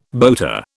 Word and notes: boater: British English

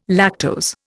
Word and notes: lactose: Indian

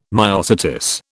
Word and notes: myosotis: British English

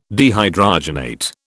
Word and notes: dehydrogenate: British English

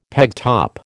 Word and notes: pegtop: American English

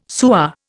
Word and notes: sewer: Indian

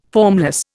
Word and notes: formless: Indian

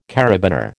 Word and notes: karabiner: American English

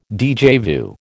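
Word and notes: déjà vu: American English

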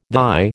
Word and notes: thy: American English

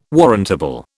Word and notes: warrantable: British English

